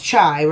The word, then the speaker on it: Chai: I'm saying it right. It's not Shy. It's Chai, right? It's spelled C-H-A-I.